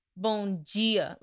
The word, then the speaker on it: bom dia: So if you say bom dia, they're like, bon dia, like the D has a G sound, and I'm like, where did this G come from?